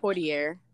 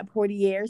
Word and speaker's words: Poitier: I would say Poitier. – Yeah, Poitier, Sidney Poitier.